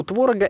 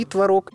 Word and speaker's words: творог: У творога их как минимум два. Правильно, и творог, и творог. Просто вариант «творог» относится к старшей норме, а «творог» – это более современное ударение.